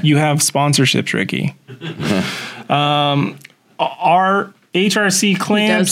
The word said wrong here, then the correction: Riquet: He does know how to pronounce his name. – Sorry, Riquet. I'm sorry. He's French.